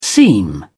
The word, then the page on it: seam: British English